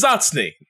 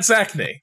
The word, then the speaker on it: Zatzny: It's Zatzny, not Zachney. You're pronouncing it wrong. And I was like, well, you know, America. And he was like, yeah, sure. But to be clear, my friend, Zatzny.